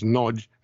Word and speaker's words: Nagy: Okay, well, I'm going to start off with an odd question, but because my surname is spelled N-A-G-Y and it's pronounced Nodge,